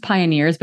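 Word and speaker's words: PYNRS: And I think to kind of round out this specific bit of news, there's another group, it's pronounced Pioneers, but it's P-Y-N-R-S, Pioneers Performance Streetwear, took to Instagram as well.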